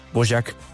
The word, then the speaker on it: Dvořák: que aquí en Colombia lo pronunciamos Dvořák, pero es Dvořák, y lo sé porque acaba de llegar de Praga y me corrigieron, es Dvořák,